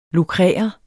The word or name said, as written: lukrere